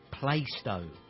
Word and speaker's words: Plaistow: It's pronounced Plaistow.